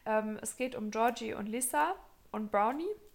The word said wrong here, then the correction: Bronwyn: Also Brown, nee, die heißt nicht Brownie, die heißt anders. aussprechen. Bronwyn.